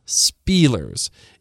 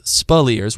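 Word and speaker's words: Speleers: And by the way, his name is Spillers, not Spielers. It's pronounced Spillers with the emphasis on the second syllable.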